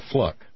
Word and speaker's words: Fluke: Now, by the way, you know, among other things that happened last night was the obvious big hit of a speech by Sandra Fluke, F-L-U-K-E. That is apparently the correct pronunciation.